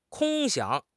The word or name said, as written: 空想